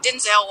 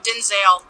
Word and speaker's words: Denzel: I've been saying Denzel Washington. – Denzel? – No, Denzel. – Denzel.